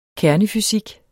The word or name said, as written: kernefysik